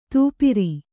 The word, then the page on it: ತೂಪಿರಿ: tūpiri